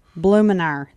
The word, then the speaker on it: Blumenauer: This is Earl Blumenauer. Blumenauer. That's right.